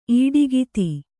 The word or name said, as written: ಇಡುಕೈದು